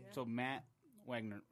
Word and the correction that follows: Wanger: I can't pronounce his last name. – Wanger. – Wanger. Mm-hmm. That's what it is.